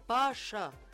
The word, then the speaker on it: باشا: باشا يا إخوانا كلمة تركية تنطق مفخمة مش مرققة. باشا بي تقيلة يعني تقيلة شوية، بي بي باشا معناها ولاية أو وزارة.